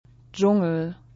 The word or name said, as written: Dschungel